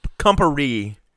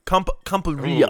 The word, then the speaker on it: Campari: The Campari, or if that's how you're saying it right, I can taste that flavor because it's very different. – Campari. – Campari? – Campari.